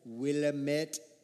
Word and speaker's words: Willamette: Jessica goes to the school, Willamette International Academy, which somebody else says a lot better. – Willamette International Academy. Yes. – That's how they say it.